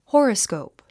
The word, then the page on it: horoscope: hɔ́ːrəskòup; ホロスコープ